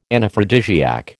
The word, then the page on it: Anaphrodisiac: American English